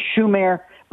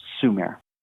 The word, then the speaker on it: Sumer: It's usually transliterated in English translations, but it's more properly understood and referred to as technically Shumer, but usually pronounced Sumer.